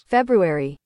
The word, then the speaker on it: February: February. – See, it's you do say the R.